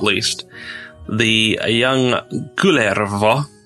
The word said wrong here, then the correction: Kullervo: or however he wishes to pronounce his name, son of Kalervo. – It's pronounced Kulervo.